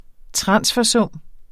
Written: transfersum

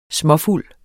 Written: småfuld

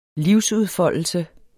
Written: livsudfoldelse

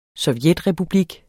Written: sovjetrepublik

